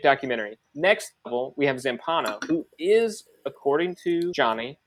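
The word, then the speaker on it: Zampanò: It's pronounced Zampano.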